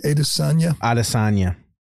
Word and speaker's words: Adesanya: How do you say it? Adesanya. Adesanya versus Dricus Duplicis? – Duplicis.